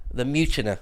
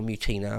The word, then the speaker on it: Mutina: That's part of the Mutina, or Mutina, I've heard it pronounced both ways, the Mutina campaign, where Mark Antony, because Mark Antony and Octavian were nominally on the same side.